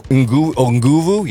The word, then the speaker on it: Nguvu: I'm going to assume that the N is silent. Yeah, Nguru? – Nguvu? – Nguvu? Yeah, yeah.